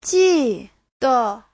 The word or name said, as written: ji4de5